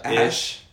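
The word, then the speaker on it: Æ: On the podcast, he said too, it's like, it's pronounced like X, and then the AE is Ash.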